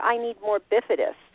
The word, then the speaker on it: bifidus: Is it I don't know if I'm pronouncing it. Bacivit. – The bifidus. Bifidus. – Thank you. Bifidus. Yeah.